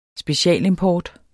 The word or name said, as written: specialimport